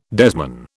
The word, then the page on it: desman: British English